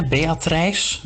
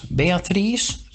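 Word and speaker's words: Beatrijs: Beatrice, Beatrice. In, de, in het middeleeuws Nederlands werd lange i nog uitgesproken als een i.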